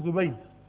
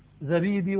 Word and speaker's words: زبيد: والزبيد يخطئ فيها كثير من الناس يقولون زبيد، زبيد عائلة مغربية. فإذا وجدتم عالم مغربي ورسمه الزبيدي تلفظونه بالضم، وإذا رأيتم عالم يمني تقولون إيش عنه؟ الزبيدي، ولا تقولون الزبيدي. الزبيدي نسبة إلى زبيد قبيلة مغربية، والزبيدي نسبة إلى اليمن، إلى زبيد يقال الزبيدي.